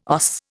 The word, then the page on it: OS: Indian